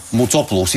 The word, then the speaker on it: Моцопулос: Сега, професор Николаос Моцопулос. – Моцопулос, извинявайте. Трудно ми е да... Винаги имам проблем с ударението в гръцките фамилии.